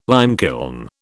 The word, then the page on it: limekiln: British English